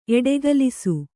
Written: ಎಡೆಗಲಿಸು

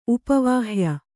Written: ಉಪವಾಹ್ಯ

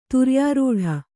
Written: ತುರ್ಯಾರೂಢ

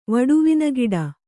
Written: ವಡುವಿನಗಿಡ

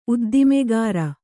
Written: ಉದ್ದಿಮೆಗಾರ

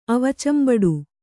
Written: ಅವಚಂಬಡು

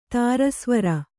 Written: ತಾರಸ್ವರ

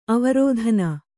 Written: ಆವರ್ತನಿ

